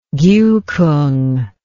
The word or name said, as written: giukung